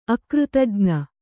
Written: ಅಕೃತಜ್ಞ

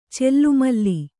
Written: ಚೆಲ್ಲುಮಲ್ಲಿ

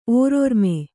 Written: ಓರಿರ್ಮೆ

